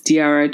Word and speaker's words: Diara: So my name is Diara Chukuigwe, and I'm the founder of Yawoni.